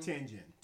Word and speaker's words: Tianjin: Tianjin.